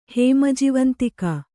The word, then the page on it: ಹೇಮಜಿವಂತಿಕಾ: kannada